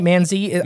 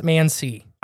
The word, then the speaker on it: Mansi: M-A-N-S-I. – Okay, Mansi. – Man's eye, Mansi. I've heard it pronounced Mansi. – I think Mansi sounds right.